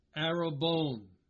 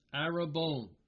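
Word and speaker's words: Arabone (ἀρραβών): The Greek word, how is it pronounced? Arabone. Arabone. It is pronounced exactly as the Hebrew word that we were just looking at in Genesis 38, translated as pledge.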